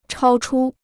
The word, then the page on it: 超出: chāo chū